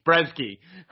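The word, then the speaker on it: Brzeziński: Dylan Potter, Lucas Brzezinski. something funny. – Your pronunciation of Polish names always gets me. It's Brzezinski.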